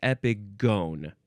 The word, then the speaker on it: epigone: So yes, it's just epigone, but it is gone because it's a long o.